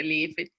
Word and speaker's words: Elif: Yeah, some people say Elif, some people say Elif. It doesn't really make a difference to me.